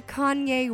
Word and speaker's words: Kanye: Ah, en el rancho lo conocemos como Kenny. Kenny West. – Sí, sí, por favor, no me ¿por qué me corriges? No, Somos de, de piquete de ombligo, amigos. ¿Cómo se dice? Se, dice Kanye West.